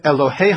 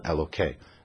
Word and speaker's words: Elohei: Hasiru, Samuel tells the people, remove, et Elohei HaNechar, it's not referring to God, so you don't pronounce it Elokei. There would be a disrespect to God.